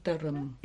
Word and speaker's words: Leitrim: The proper pronunciation is Lovely Leitrim. – Yeah, three syllables in each word.